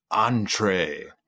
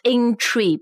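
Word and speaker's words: entrée: Entree. – Entree. 那它的拼法是 E-N-T-R-E-E. 第一个 E 上面还有个像我们的第二声拼音那样的。But the pronunciation is entree. It's not intree, 不像那个 inter, 对吧 ?intree, it's entree.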